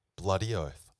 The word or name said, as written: bloody oath